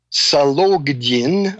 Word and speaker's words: Sologdin: While Rubin is a dedicated, devoted communist, Sologdin, I'll pronounce that slowly, has a very different philosophy of life.